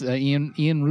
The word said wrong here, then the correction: Ilan: Rubin? How do you pronounce the drummer? – I think it's Ilan. – Oh, Ilan. Yeah.